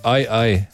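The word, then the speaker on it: aye-aye: Uh, ime živali je smešno, ne vem, kako se izgovori, aj, aj, ali pa aj, aj, a, y, e. – Pogojem, moraš malo e. višji ton. Aj, aj.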